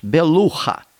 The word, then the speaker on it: beluga: I don't know if you can hear this difference in pronunciation, but this beluga, this how, like in home, maybe, house, beluga.